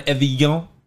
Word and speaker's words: Evian: So the N is gone. Evian.